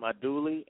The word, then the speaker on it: andouille: You need some andouille, and andouille, and that's spelled A-N-D-O-U-I-L-L-E.